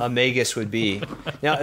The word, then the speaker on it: Magus: So like if it is Magi, so the that's a plural of Magus, M-A-G-U-S, which I think is pronounced with a hard G.